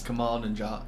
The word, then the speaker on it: Kumail Nanjiani: So now on to the film, which was Stuber, starring, I'm probably going to butcher this, Kumail Nanjiani, I believe his name is. – Kumail Nanjiani.